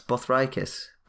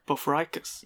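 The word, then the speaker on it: Bothriechis: So yeah, similarly to the chameleons we discussed a couple of episodes ago, these pit vipers of the genus Bothriacus, which I used to pronounce Bothriacus, but... – Bothriacus. – Yeah, I was told by... it's actually Jonathan Clegg who told me, he was like, that's not how you pronounce it.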